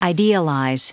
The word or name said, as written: idealize